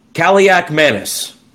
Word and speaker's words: Kaliakmanis: I'm excited for the kid who was a youngin last year, and this is going to look crazy on paper, but I promise you this is the correct pronunciation. Ethan Kaliakmanis, the Greek rifle, as they refer to.